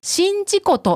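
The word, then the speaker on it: 宍道湖: 島根県の湖は、新事故と読みます。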